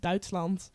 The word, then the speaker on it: Duitsland: And Germany is Duitsland.